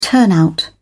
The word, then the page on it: turnout: British English